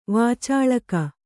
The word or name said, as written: ವಾಚಳಕ